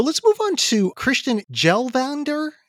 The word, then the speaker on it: Kjellvander: You don't pronounce the K, right? – Yeah. This is a must hear album. So it's K-J-E-L-L and then Vander, V-A-N-D-E-R. But it's, you know, I think it's pronounced Kjellvander.